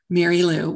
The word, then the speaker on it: Merrylue: Yes, my name is Mary Lou Martin. And Mary Lou is spelled a little unusually. It is MERRYLUE. Mary Lou, one word.